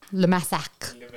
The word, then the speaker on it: le massacre: Yeah, it's le massacre. Le massacre. Le massacre is how it'd be pronounced.